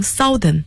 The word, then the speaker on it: southern: 那么南方的英文是 S-O-U-T-H-E-R-N，要注意发音，很多人有可能读成 southern，但是正确的发音是 southern， OU 字母组合读成了啊这样的声音。